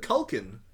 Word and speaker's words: Culkin: How do you pronounce it? – Culkin. There's only one L.